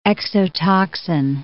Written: exotoxin

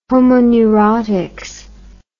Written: homo neuroticus

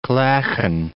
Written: clachan

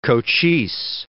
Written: Cochise

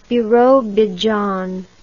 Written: birobizhan

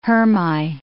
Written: hermai

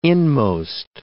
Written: inmost

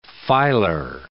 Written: filar

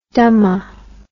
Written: dhamma

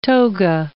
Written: toga

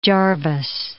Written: Jarvis

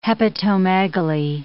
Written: hepatomegaly

